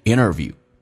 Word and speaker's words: interview: 但关键是这边一个听力难点，就是 job interview，首先 interview 这个单词 nt 组合，首先 t 可以不用发音变成 interview。